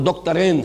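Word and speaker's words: دكتورين: وہ دکترین ہیں، میں عربی تلفظ استعمال کر رہا ہوں، دکترین دو ڈاکٹرز ہیں۔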